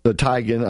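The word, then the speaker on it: Tiegen: Tigan, I guess it is. It's pronounced John Tigg Tigan and Mark Oz Geist.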